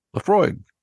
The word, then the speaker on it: Laphroaig: And that is Laphroaig. – Laphroaig.